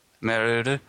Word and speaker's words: Meruru: Atelier Maruru. Is that really how you... Meruru? How would you say it? – Meruru.